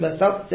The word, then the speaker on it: بسطت: هنا مو تلفظ السين صاد بسط غلط بسطت وزاده شنو بسطة في العين لا بسطة بسطة نخبنا لأن الحروف اللي كمت بدل السين مع الصاد في الإدغام فأنا أقول لئن بسطت بسطت ما أقول بسطت شوف فرق.